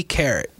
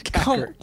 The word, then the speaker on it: Kakarot: Dragon Ball Z Carrot. – No. – PS4. – Say it right. – Kakarot.